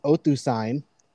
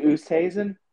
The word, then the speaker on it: Oosthuizen: And Luis Oosthuizen, I hope I did not mispronounce that, but I probably did. – Luis Oosthuizen? – Yeah, that guy. I'm so glad that you can correct me.